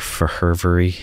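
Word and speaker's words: Fehervary: Marty Favari, or Fervery, as I've heard it pronounced as well.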